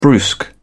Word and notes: brusque: British English

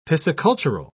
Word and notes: piscicultural: British English

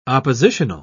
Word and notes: oppositional: British English

